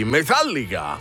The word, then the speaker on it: Metallica: Marian Faithful nella chiusura poi di The Memory Remains dei Metallica, perché voglio ricordare che si pronuncia così,